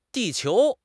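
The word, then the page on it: di4 qiu2: tones 4, 2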